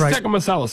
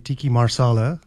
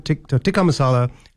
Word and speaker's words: tikka masala: So what is tikka masala sauce? And if I didn't say that right, I apologize. – You said it better than calling it tiki marsala.